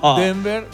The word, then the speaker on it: Denver: Denver, sí, bien, bien pronunciado.